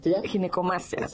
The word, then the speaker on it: ginecomastia: Para empezar, la palabra, le decía, ¿cómo se pronuncia? Ginecomastía. – Sí, exacto. Ginecomastia, ok.